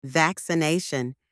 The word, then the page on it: vaccination: anglais